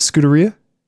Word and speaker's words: Scuderia: How do you say it? Scuderia? Mm-hmm.